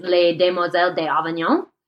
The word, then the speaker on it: Les Demoiselles d'Avignon: One of the most essential cubist works, one of the most well-known Picassos, is Les Demoiselles d'Avignon.